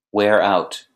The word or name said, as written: wear out